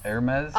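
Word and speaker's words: Hermès: Is that how you say it? Hermes. – Hermes.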